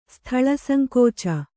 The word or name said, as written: ಸ್ಥಳಸಂಕೋಚ